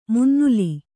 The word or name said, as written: ಮುನ್ನುಲಿ